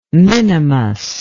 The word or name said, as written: minimus